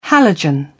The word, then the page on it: halogen: British English